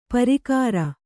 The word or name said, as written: ಪ್ರಾಕಾರ